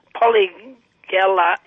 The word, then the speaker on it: Polygala: Polygala X.